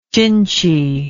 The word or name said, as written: chinchy